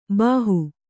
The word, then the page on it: ಭಾಹೊ: bhāhū